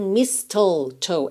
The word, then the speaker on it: mistletoe: Temos aqui mais uma palavra que a gente não vai pronunciar a letra T, tá? A gente não pensa em mistletoe, é mistletoe. Pensa em dois S's mesmo aqui, tá?